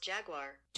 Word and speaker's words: jaguar: How do you pronounce Jaguar? – That's pronounced Jaguar. – Jaguar. – Jaguar. – Thank you. – G-W-A-R, like that band.